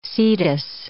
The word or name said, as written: Cetus